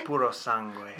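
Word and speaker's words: Purosangue: Puro sangue? – Puro sangue.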